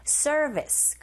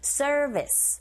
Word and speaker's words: service: Sieve 잡아주시고 서비스가 아니라 우리식 발음이 아니죠. R이 들어가 있기 때문에 service 그리고 B가 아니라 V인지 역시 긁어주시고 service for the island.